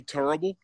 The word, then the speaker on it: Turrible: Turrible, just like Charles Barkley says it and pronounces it, T-U-R-R-I-B-L-E.